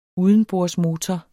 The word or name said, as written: udenbordsmotor